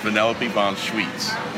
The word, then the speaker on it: Vanellope von Sweets: Did I say it wrong? – Sweets. – Sweets. – Sweets. – Sweets? – Yeah. Sweets. Vanellope von Sweets.